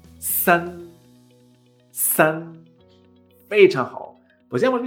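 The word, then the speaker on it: san (三): El número 3 en chino es S-A-N, en primer tono. Se pronuncia san, san, san. ¿Notas? Esa S de Sevilla.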